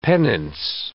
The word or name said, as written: penance